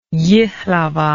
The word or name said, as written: Jihlava